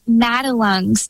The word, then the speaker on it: Madelung's: So when were you diagnosed, and I'm not sure that I am saying this right, but Motherlooms, how do you say that? – Yeah, it's very rare, so I don't blame you for being unsure about the pronunciation. It's pronounced Madelung's deformity, and I can set the scene for you a bit.